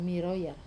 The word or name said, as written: maroya